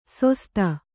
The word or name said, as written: ಸೊಸ್ತ